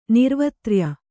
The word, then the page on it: ನಿರ್ವತ್ರ್ಯ: Kannada